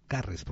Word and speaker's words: Garres: Eh, después nos comentó el dibujante Rafa Garres, que, yo lo mencioné, bueno, Garres, porque no tiene acento en la E, que, yo lo mencioné y él apareció en el muro diciendo, Alguien me ha invocado en este podcast.